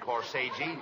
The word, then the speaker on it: corsage: No, not corsage. – Corsage. – That's corsage. Spelled A-G-E is pronounced like odge, as in corsage or garage.